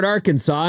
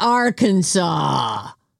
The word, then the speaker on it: Arkansas: It's illegal to mispronounce the word Arkansas in Arkansas. You probably won't get arrested for it, but it's not Arkansas. Don't do it.